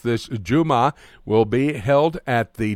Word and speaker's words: Jumah: And they call it, and how do you pronounce from the Arabic word, J-U-M-A-H? – That's the word Jummah, which means Friday.